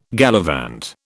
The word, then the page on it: gallivant: British English